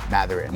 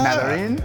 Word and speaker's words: Mathurin: Benedict Mathurin? No. Hard no? – Yeah, there's no TH. – Mathurin. – No, Mathurin. – Mathurin. That's so bad.